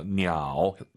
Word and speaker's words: 鸟: 삼성이 마지막 글자면 올리지 마세요. 냐오 하지만 사실은 보통 량지냐오. 더 중국스럽게 발음하시려면 반삼성으로 딱 끝냅시다. 이 토끼는 아주 크다.